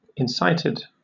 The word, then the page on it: incited: British English